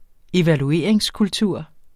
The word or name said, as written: evalueringskultur